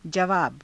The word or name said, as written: javaava